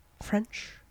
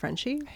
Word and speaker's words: Frenshe: It's spelled F-R-E-N-S-H-E. French, but it's apparently pronounced Frenchy.